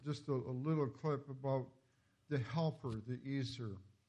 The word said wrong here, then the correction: ezer: Actually, the right pronunciation is Azer, but I've always, not Jewish, so I mispronounce it.